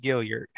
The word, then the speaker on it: Gilliard: But yeah, I go with the US DuBois and the hard G Gilliard.